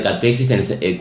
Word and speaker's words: catexis: Dice esta catexis, ¿se ya pronuncia así? ¿Catexis? – Sí, es palabras de las psiquiatrías de sí, está bien.